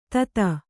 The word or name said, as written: ಥತ್